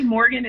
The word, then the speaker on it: Morghan: Morgan is spelled M-O-R-G-H-A-N.